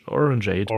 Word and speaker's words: Orangeade: Wie Lemonade nur mit Orange. Orange, Orangeade.